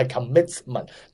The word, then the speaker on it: commitment: 咁因为个 T 如果系 commitment，但系如果个 T 喺中间个字咧，基本上我哋都唔使发音嘅，因为系好麻烦、好翘口啊。